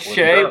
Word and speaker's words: Shea: It's Shay, but close enough.